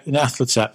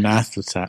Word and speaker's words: Nyarlathotep: Yeah, Nyarlathotep, it's our game. Yeah. Not your game anymore, H.P. Lovecraft, it's ours. Yes. We call him Nyarlathotep.